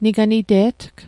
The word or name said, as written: niganite'tg